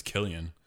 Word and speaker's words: Cillian: Just like me with Cillian Murphy, learning it was Killian.